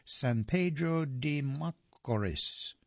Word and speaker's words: San Pedro de Macorís: He was born on January the 2nd of 1999 and his hometown, San Pedro de Macos. I don't know how to pronounce it. San Pedro de Macorís, Dominican Republic.